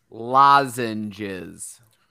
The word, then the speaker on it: lozenges: Try it again, one more time. – Lozenge. How is it? What? No, not again. – Once more. – Lozenge. Is there a Z in it? – Yeah, this is Z. – Lozenge. How do you say it? I know what it is. If I typed it, I could spell check it. I get it. – Lozenges. – Lozenges? – Yes.